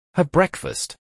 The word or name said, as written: Have breakfast